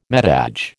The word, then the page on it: metage: American English